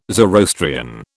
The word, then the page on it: Zoroastrian: British English